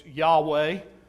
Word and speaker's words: Yahweh: The Hebrew people pronounce it differently than we do in English as Yahweh.